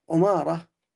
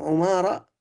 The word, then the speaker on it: عمارة: نعم، ونحو من هذا النوع أيضا عمارة، كله يقولون كل ما ورد من قولهم عمارة فهو بضم العين إلا عمارة الصحابي فبكسرها.